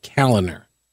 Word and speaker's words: Kaliner: I'm at Pete Kaliner. Yes, I will spell that for you. K-A-L-I-N-E-R. Oh, yeah, P-E-T-E.